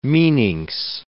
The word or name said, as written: meninx